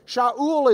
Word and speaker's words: Shaul: Because the Greeks didn't have an SH sound. They couldn't have even pronounced his name. He gets out in the Greek world and they're like, how do you say it? They don't have a sh sound in Greek. So they just do S, sa. So Shaul becomes Saul, Saul, we read it.